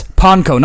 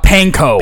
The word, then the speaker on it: panko: Which for everyone who is going to pronounce it, it's panko, not panko. – Panko chicken. – It's a Japanese word.